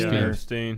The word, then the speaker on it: Epstein: I think it's Eppenstein or Epstein. – Epstein.